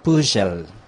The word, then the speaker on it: Püschel: Püschel. – Puschel.